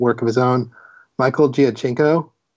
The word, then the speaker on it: Giacchino: His name is pronounced Giacchino.